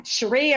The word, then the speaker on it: Cherie: Yes, you are. Cherie, it rhymes with Marie.